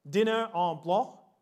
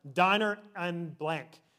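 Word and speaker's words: dîner en blanc: Have you ever heard of dinner en blanc? I think that it's actually pronounced diner en blanc. It's French for diner in, or dinner in white.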